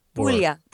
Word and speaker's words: Puglia: Is it Puglia? – Puglia.